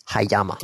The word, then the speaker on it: Hayama: Hayama.